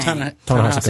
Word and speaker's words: Ta-Nehisi: Ta-Nehisi Coates.